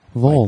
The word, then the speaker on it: Vol: Not V-O-H-L. – Uh-huh. – I messaged the band and said, how do you pronounce it? Is it V-O-H-L or is it pronounced as a word? And he said it rhymes with bowl. – Huh. Vol.